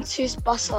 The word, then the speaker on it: Tsubasa: But I'm gonna have to say Captain Tsuba. Like, it's a football anime. I don't know if I spell it wrong. Spell it right. Captain Tsuba. It's Captain Tsubasa or whatever.